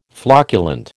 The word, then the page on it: flocculent: American English